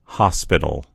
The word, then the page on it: hospital: American English